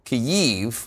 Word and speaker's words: Kyiv: CNN senior international correspondent Matthew Chance joins us now live from Kyiv, which, as I understand it, is how the Ukrainians pronounce it, or something like that.